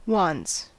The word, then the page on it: wants: American English